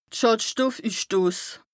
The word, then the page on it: de Schàdstoffüssstos: Bas Rhin